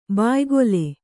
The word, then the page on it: ಬಾಯ್ಗೊಲೆ: bāygole